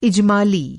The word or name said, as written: इजमाली